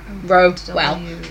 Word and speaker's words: Rowell: And the one that has started to rise up is Rainbow Rowell, and she... – Rainbow what? – Rowell. – Okay. – I hope I'm pronouncing her name rightly. – How do you spell that? – R-O-W-A-E-L-L. Rowell. Rowell. Rowell.